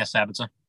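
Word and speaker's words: Sabitzer: And we just found out that Marcel Sabitza, am I saying that right? – Yeah, Sabitza. – Sabitza, I always mess up his last name, is also out for us this season.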